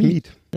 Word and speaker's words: Mieth: Nicole Mief. Miet? Wie wird das ausgesprochen? – Schreibt man es denn? – M-I-E-T-H. – Dann wahrscheinlich Miet. – Ja. Miet, okay.